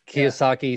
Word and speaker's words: Kiyosaki: Kaya, Kaya, I can't say his name. Kayas. – Kiyosaki, I think. – Kiyosaki yeah.